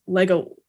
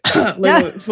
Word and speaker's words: Legolas: She pronounced Legolas as Lego, like, I can't remember what she would say, but it was something that I would constantly just be like, Legolas, like, just say it.